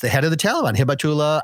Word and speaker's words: Hibatullah: We have Habutala Akunzada, who is, I hope I'm pronouncing that right. – No. Hibatula. – Hibatula.